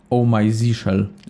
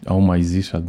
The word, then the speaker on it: Oh My Zsh: Oh My Zishel. Oh My Zishel, dobrze, no.